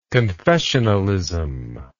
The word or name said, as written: confessionalian